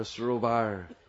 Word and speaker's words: sarovar: A sarovar, as Maharaj likes to say. Our Western tongues butcher all the nice Brijbhasa words. It's sarovar. It's a short A.